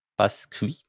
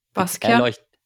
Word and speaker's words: Basquiat: Basqui? Ah, Bas Michelle. – ja, bitte probier es aussprechen. – Basqui, keine Ahnung. Äh, Basquiat.